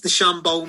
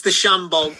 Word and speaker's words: DeChambeau: It's DeChambeau, mate.